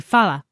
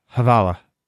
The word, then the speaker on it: hvala: As I said in the voicemail played on the air, hvala, And I was tutored on the forums how to pronounce this. You have to pronounce the H. – Or if you're lazy, you can say Fala. – Havala.